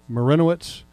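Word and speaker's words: Marynowitz: But in Jaguar news, the Philadelphia Eagles have named their head personnel guy, and it goes to Ed – let me make sure I say this right – Marinowitz. Is that right? – Marinowitz.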